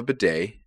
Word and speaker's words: bidet: I have a bidet, and it makes my butthole not chafed when I wipe.